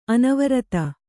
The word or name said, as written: ಅನಾವರ್ತ